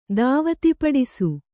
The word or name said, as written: ದಾವತಿಪಡಿಸು